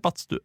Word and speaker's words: badstu: Ja, men du sier uttaler med det da, ikke med te. – Ja, men det jo det jeg sier. Battstu, sier du. – Ja, jeg sier badstu.